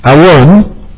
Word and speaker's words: awon: Avon, with Avon, some will pronounce it Avon, it's A-W-O-N.